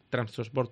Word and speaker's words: Trabzonspor: Trabzonspor turco,